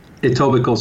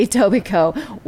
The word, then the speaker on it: Etobicoke: Etobicoke Sport Med and Physiotherapy. – Okay, yeah. Etobicoke.